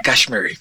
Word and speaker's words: cashmere: Tu batita de Casemiro, ¿cómo se dice? ¿Cashmere? – De Cashmere.